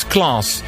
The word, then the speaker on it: KLAS: Another rather new Irish medium wave station is called KLAS, pronounced as class.